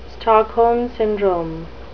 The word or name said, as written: stockholm syndrome